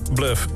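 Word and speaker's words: Bløf: En dat wordt uitgesproken als Bluff.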